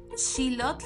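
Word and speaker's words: Xilotl: Claro, bueno, pues eh, lo pueden buscar como Radio Shilot y se escribe X I L O T L y como bien ya lo dijiste,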